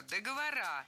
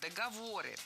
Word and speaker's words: договоры: Вторая ошибка – договора. Более корректный вариант – договоры. При склонении ударение в слове «договор» сохраняем на третьем слоге. Договоры, договоров, договорами.